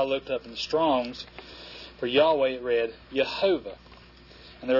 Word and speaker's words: Yahweh: This quote says it is generally acknowledged that Yahweh is the correct pronunciation of the memorial name of God proclaimed at the bush. Philologists and theologians agree in the term Jehovah, frequently used instead of Yahweh, is a spurious form of the word."